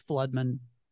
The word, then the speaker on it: Flodman: Oh, it's Flodman.